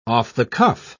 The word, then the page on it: off-the-cuff: British English